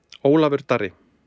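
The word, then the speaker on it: Ólafur Darri: Olafur Darri.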